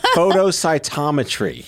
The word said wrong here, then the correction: flow cytometry: Flow cytometry. Flow cytometry.